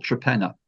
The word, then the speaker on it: Torpenhow: There's a place in England called Trepenna, which is spelt Torpenhow.